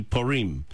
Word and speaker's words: Purim: It's pronounced several ways. In English, you you can also kind of just go with what's comfortable. You can go with Purim or Purim. In Hebrew, it'd be Purim. So either way, whatever's comfortable for you, go with that.